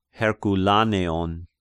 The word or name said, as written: Herkoulaneon